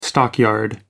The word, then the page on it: stockyard: American English